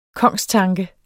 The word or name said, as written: kongstanke